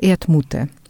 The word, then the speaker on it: Erdmute: Moje imię właściwe to jest właściwie Erdmute, a wymawiałoby się prawidłowo Erdmute.